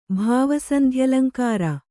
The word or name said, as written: ಭಾವಸಂಧ್ಯಲಂಕಾರ